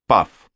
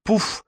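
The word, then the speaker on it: puff: По-английски слово это произносится как «паф», но транскрипция «пуф» предпочтительнее, потому что ее использовали русские литераторы первой половины XIX века.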